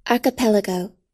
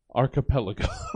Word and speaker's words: archipelago: Archipelago. – Archipelago. – I did the same thing. I looked up several times how to pronounce it. – Yeah. – I always thought it was archipelago. It is archipelago.